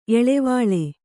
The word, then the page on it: ಎಳವಾಳೆ: eḷavāḷe